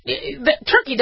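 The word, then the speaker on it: Türkiye: When you ask if the ambassador to Turkiye is here, that's how you say it.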